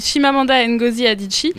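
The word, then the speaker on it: Chimamanda Ngozi Adichie: oui, qui s'appelle Shimamanda Ngozi Adichie.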